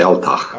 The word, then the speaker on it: Altach: I'd say Altach.